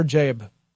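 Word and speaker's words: Jaeb: So back in 1989, there was a guy named Cameron Christopher Jabe, who it seems goes by Chris Jabe these days. And I may even be mispronouncing that. It's J-A-E-B. If I were doing it as Old English, it would be Jab.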